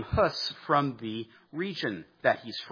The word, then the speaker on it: Hus: Second man that I want to introduce you to, Jan Hus. It's spelled H-U-S. It's Czech, which is why it's not pronounced quite the way that we might expect.